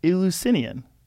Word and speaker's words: Eleusinian: Eleusinian, that's it.